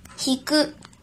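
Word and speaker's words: 引く: 训读的时候读作“ ひく”，“ ひく”，“ ひく”。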